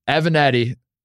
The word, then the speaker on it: Avenatti: Avenatti.